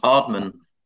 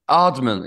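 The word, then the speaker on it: Aardman: Eh, eh, uttales det Ardman? Animasjonsselskapet? Um, Ardman. Ardman, ja.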